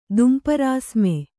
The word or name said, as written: ದುಂಪರಾಸ್ಮೆ